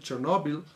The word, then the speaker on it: Chernobyl: Chernobyl o, Chernobyl, creo que se pronuncia Chernobyl,